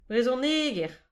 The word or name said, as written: brezhoneger